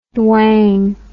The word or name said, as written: dwang